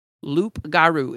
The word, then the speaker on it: loup-garou: Loup-garou, if you're going to say it and you're like, not Cajun, you say loup-garou is how it looks like it's spelled.